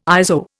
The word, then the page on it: ISO: Indian